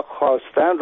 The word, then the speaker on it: خواستن: به عنوان مثال ما خواهر یا خواستن رو با واو می‌نویسیم، در حالی که این صدا آ هست. علتش اینه که در گذشته خواهر یا خواستن تلفظ می‌شده. در به اصطلاح زبان کهن فارسی، در زبان میانه فارسی یا پهلوی، این خوا خوی ما زیاد داشتیم.